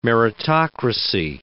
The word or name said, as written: meritocracy